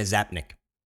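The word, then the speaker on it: Czapnik: It's Dana Zapnik. – How the hell would you know? – Because the C is going to be silent there.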